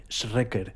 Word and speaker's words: Schreker: Se escribe S-C-H-R-E-K-E-R, Schrecker.